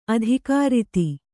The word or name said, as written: ಅಧಿಕೃತ